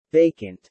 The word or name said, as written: vacant